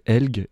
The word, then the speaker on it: Elg: On peut prononcer Elg également. Ça s'écrit tout simplement E-L-G.